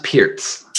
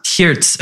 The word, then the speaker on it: pirts: I believe it's pronounced Pirts. – Pirts, okay.